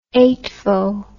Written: eightvo

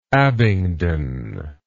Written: Abingdon

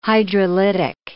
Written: hydrolytic

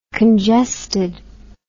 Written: congested

